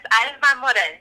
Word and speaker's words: Alma Morel: Am I saying that right? Alma Morel. I'm saying it with a little bit of Italian accent. Alma Morel. – Yes, Alma Morel. Yeah, you did good.